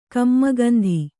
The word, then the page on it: ಕಮ್ಮಗಂಧಿ: kannada